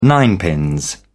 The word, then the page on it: ninepins: British English